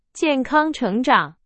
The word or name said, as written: 成长